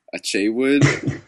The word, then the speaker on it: Achewood: Akewood. – Isn't it pronounced Achewood? – Achewood.